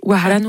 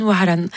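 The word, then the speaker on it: Waran (وهران): Waran, je prononce mal. Vas-y. – Tu es vraiment très proche. Waran, Waran, qui veut dire Oran.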